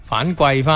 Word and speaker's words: 反馈: 咁呢个反季咧就系我哋其实以前一路都叫反馈啊吓咁样样嘅一个讲法，馈或者反馈啊咁样，但系后来查翻咧，即系正确嘅读音咧就系季嘅吓。反季翻咁样样吓，OK，